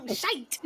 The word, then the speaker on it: shite: but they're Irish, so it rhymes with might. – Yes, there we go. Oh, shite.